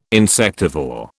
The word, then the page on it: insectivore: British English